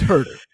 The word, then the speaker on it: Huerter: you realize that it's probably not pronounced like that. it's Herder.